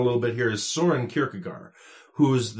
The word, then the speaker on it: Kierkegaard: After hearing the recommendation from Wikipedia as to how Kierkegaard's name should be pronounced, I'm more confused than ever. Here's how it sounds. – Søren Åby Kierkegaard.